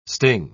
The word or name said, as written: sting